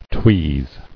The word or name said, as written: Tweeze